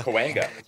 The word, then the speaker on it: Cahuenga: Cahuenga, yes.